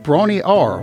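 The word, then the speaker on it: Bron-Yr-Aur: Brawny Ar, which is a remote cottage in Wales, and yes, that's about how it's pronounced,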